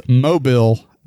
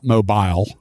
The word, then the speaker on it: Mobile: Yes, it's Mobile, Alabama, not Mobile, Alabama.